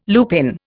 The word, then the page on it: lupin: Indian